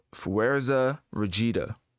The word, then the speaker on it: Fuerza Regida: So, Fuerza Regida. Yeah, that's how you say it.